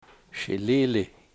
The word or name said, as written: shiliili